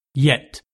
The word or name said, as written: yet